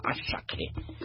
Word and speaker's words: Asake: Asake ni mwanamuziki ambaye anatamba sana, lakini wengi wanamuita au anatamkwa Asake.